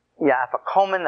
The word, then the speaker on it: afikomen: And then you have the Passover meal, and the afikomen afikomen, that's the way it's pronounced, is the actual where you eat the second part.